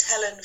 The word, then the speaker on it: Helen: My name is Helen Fagelman and it's spelled H-E-L-E-N-F-A-G-E-L-M-A-N.